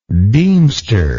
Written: deemster

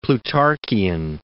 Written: Plutarchian

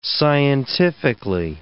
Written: scientifically

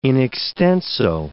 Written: in extenso

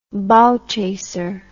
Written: bow chaser

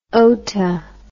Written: ota

